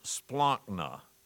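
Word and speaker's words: splankna (σπλάγχνα): My favorite Greek word in the New Testament is this, and it's pronounced splankna. I just love the word splankna.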